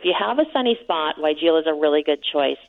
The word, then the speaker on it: weigela: What about, how do you say it, the weigela? – Weigela, yeah. So people don't know how to pronounce that plant, but it is weigela.